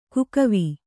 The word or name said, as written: ಕಾಕಿವೆ